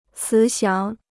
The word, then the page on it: 慈祥: cí xiáng